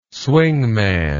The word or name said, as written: swingman